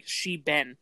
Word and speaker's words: shebeen: it may go back to the Irish word shebeen. I don't know if I'm pronouncing it right. It's literally pronounced like she-ben, like she's been somewhere. That's literally how it's pronounced, like spelled.